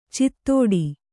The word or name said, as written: ಚಿತ್ತೋಡಿ